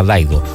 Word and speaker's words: Dido: Algunos le dicen Dido, pero me contaban que hace tiempo que se pronuncia Daido.